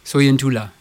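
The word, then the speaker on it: Sointula: Tu peux me le redire ? – Soyen Tula. – Soyen Tula.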